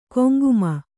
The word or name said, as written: ಕೊಂಗುಮನ